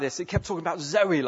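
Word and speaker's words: Zoe (ζωή): It sounds like the word Zoe, the name Zoe. I thought you might. It sounds like the name Zoe.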